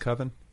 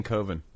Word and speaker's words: coven: Is it pronounced coven? – Yeah. – I've been saying coven for way too long.